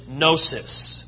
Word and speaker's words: gnosis: The Greek word for knowledge is gnosis. G-N-O-S-I-S.